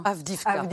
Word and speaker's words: Avdiivka: Joe Biden, cet après-midi, fait le lien entre ce recul de l'armée ukrainienne à Avdiivka. Je ne sais pas si je prononce bien. – Avdiivka. – Voilà, donc j'ai mal prononcé, c'est très clair.